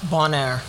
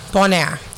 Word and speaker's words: Bonheur: How you pronounce that? – Bonaire. – Bonaire.